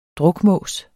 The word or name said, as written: drukmås